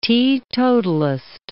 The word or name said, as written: teetotalist